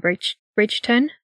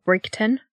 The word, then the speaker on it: Bridgeton: She lived in Bridgeton, which is sometimes pronounced Brigton.